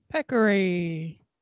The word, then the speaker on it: peccary: Peccary? – Peccary. – Yeah, like peck, like pecker.